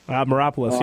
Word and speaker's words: Maropoulos: I like Tyra Maropoulos, if I'm saying that correctly. – Maropoulos, yeah.